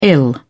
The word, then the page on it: ill: British English